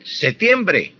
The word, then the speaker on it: septiembre: Tanto septiembre, oígase bien, septiembre, pronunciando la P, con P, y también septiembre, sin la P.